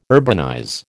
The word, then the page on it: urbanize: American English